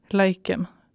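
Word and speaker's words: Leikeim: E L-E-I-K-E-I-M. Laikem.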